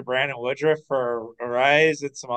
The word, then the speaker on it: Arraez: Now, I did see yesterday that Luis Arraez, is that how you pronounce it, the Twins guy? – Luis Arraez. – Arraez was Arise, basically, yeah.